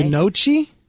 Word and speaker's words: gnocchi: It's spelled G-N-O-C-C-H-I. – Okay. – Gnocchi? – Gnocchi.